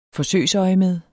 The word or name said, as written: forsøgsøjemed